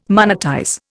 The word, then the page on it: monetize: Indian